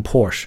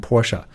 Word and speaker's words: Porsche: 我不，我并不喜欢去纠正别人的读音，但是既然今天在做这个节目，所以就跟大家讲一讲，这个词尾音是要读出来的，一定要读成 Porsche，对吧？毕竟这是人德国人的名字，人家的名字就这么读的，对吧？我们也不能强求他叫 Porsche，所以他应该读成 Porsche，尾尾尾上还有一个 a 的音在后面。BMW 这个词就没什么好说的了。